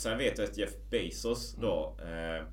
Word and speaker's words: Bezos: Men jag läste ju också Amazon och Jeff jag vet inte om man uttalar Jeff Bezos efter. – Ja. Men det är mm. Bezos eller? Bezos. Bezos. Ja. ja.